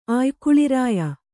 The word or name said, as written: ಆಯ್ಕುಳಿರಾಯ